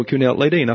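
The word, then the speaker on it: Kuehnelt-Leddihn: And the Kuhnelt, he said Kuhnelt. Kuhnelt. And he was sort of bend his knee. I said, okay, I got that, Kuhnelt. And then Leydin. And so Kuhnelt-Leydin, I finally got that. So that's the correct pronunciation of this fellow's name.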